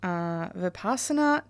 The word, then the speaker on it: Vipassana: Okay, so I guess to start today, could you tell us a bit about Vipassana, tell me if I'm saying this wrong, meditation? – Mm-hmm.